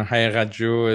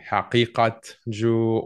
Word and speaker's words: Haqiqatjou: The Q is what people have trouble with. It represents a cough in Arabic or Farsi. So you can pronounce like a hard cough, like Haqiqatjou, or softer Haqiqat, like more like a rain sound. Haqiqatjou, either way.